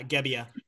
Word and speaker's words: Gebbia: You know, that redshirt junior, Tristan, how do you pronounce that? Gabia? – Yeah, Gebbia.